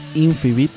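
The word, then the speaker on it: Infibeat: Eso que sonaba de último se llama Volar de nuevo de la banda Infibit. Se escribe I-N-F-I-B de bueno, E-A-T de tomar, como les gusta a los ticos.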